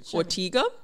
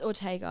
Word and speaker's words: Ortega: And the whole thing was directed by Kenny, I can't pronounce this last name, Ortega, who was the director of the High School Musical series. – Oh, it's Ortega, I think. – Ortega, okay, there you go.